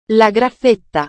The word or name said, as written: la graffetta